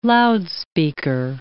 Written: loudspeaker

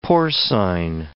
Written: porcine

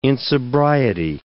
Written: insobriety

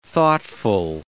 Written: thoughtful

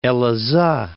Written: Elazig

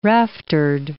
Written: raftered

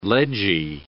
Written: ledgy

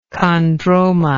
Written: chondromatous

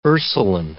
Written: Ursuline

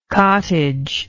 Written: cottagey

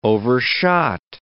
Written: overshot

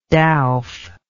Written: dowf

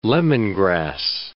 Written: lemongrass

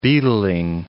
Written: beetling

